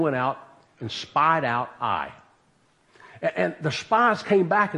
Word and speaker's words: Ai: It's spelled Ai, but it's called Ai.